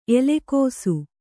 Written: ಎಲೆಕೋಸು

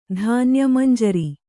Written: ಧಾನ್ಯಮಂಜರಿ

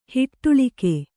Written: ಹಿಟ್ಟಳಿಕೆ

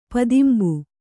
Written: ಪದಿಂಬು

